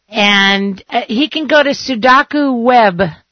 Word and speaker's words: Sudoku: And also the Sudoku.